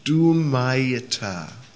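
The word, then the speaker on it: Dhumayita: D-H long U, M long A, Y-I-T-A. Dhumaita. Did you get that? Second one, blazing, Jwalit, J-V-A-L-I-T, short I, Jwalit.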